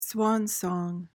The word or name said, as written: swan song